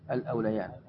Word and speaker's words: الأوليان: يا أيها الذين آمنوا شهادة بينكم قد ذكرتها في الكتاب الكريم المشكل، فإن عثر أي ظهر الأوليان هي تكتب بالياء لكن تنطق بالألف الأوليان في قراءة أوليان يعني فيها عدة قراءات.